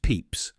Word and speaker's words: Pepys: A man named Samuel Pepys, pronounced Peeps, wrote about everything he saw during the fire in his diary.